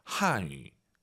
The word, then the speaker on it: 汉语: 한语. 중국어 발음하실 때 한위 아니고 와유 발음은 입술 동그랗게 말아서 한语. 근데 한국어는 뭐죠? – 한语.